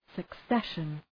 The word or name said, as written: succession